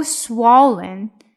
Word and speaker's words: swollen: And the front door is so swollen, swollen swollen 哈，不能把 l 读成二的音。